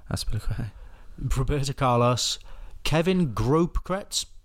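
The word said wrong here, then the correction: Großkreutz: Don't know. – Grosskreutz. – Something like that. Wayne Rooney, Zlatan. – Did you just pronounce that German B looking thing as a B? – Yeah, I don't know.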